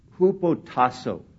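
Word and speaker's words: hupotasso (ὑποτάσσω): Now, the word submissive, the word that is translated submissive or submit, is a Greek word that is pronounced hupotasso. Hupotasso.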